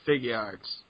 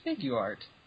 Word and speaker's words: Figuarts: Figure out how the fuck you pronounce Figuarts. – Like, Figuart. – Figuart? – Or Figuart. – Figuart? – Because they're figures, but they're art. So it'd be Figuart, which sounds like some weird Armenian food.